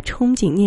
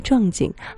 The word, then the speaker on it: 憧憬: 上个节目不小心把憧憬念成了壮景，好事者就坐实了她没文化的标签儿。